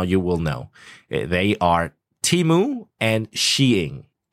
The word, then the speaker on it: Temu: T-E-M-U, Temu.